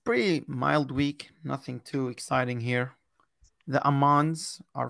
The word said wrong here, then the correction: Amiens: So, Amiens against Saint-Ouen.